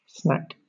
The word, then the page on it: snack: British English